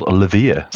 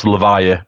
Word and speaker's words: Lavire: Some people call us Lavire, but that's fine. There became a bit of a running joke in Scotland that people kept saying we rhyme with beer, so they kept calling us Lavire, and that helped people, so that's fine.